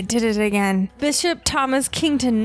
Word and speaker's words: Kington: Kington Fort, and I'm going to say this spelled K-I-N-G-T-O-N. I have to spell it out because I kept wanting to call it Kingston Fort because it's Davis County.